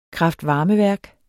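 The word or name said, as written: KRAFTVARMEVÆRK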